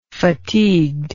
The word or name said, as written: fatigued